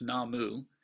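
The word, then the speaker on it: Namu (南無): And added to that, to the front of it as a prefix, are the two characters that in Chinese would be Namo, and in Japan it's pronounced Namu.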